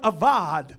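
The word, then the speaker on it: avad (עבד): Now, serving is the Hebrew word avad. It's spelled A-B-A-D, but it's pronounced like it's spelled A-V-A-D. Avad.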